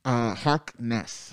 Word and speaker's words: Harkness: Harkness.